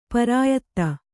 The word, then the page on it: ಪಿರಿಯಾದಿ: kannada